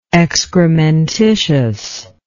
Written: excrementally

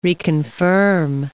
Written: reconfirm

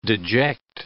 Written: deject

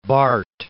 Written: Barth

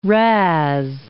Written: razz

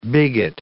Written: bigot

